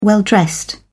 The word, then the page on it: well-dressed: British English